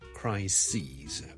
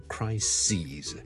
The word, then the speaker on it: crises: 我哋头先讲咗最常见嘅例子就 crisis 啦 ,c-r-i-s-i-s, 一个危机啦 ,a big crisis 啦，但 number of crises, ok, 留意个发音啊，串字当然係唔同啦，但个发音係唔同 ,crisis 同 crises。